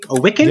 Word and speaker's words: Wiccan: So their beer names are symbolic of a Wiccan.